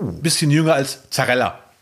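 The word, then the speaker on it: Zarrella: Zarella. – Das ist Zarella mit Z. – Okay. Z, wie wir Deutschen das aussprechen, so heißt der.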